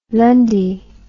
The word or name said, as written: lundy